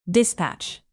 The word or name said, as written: dispatch